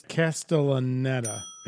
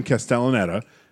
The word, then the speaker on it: Castellaneta: It's Castellaneta. – Is that how you pronounce it? – Well, I'm looking at the, because I knew who he was. – He's Homer Simpson. – He's Dan Castellaneta. We were dropping a syllable.